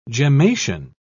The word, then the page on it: gemmation: British English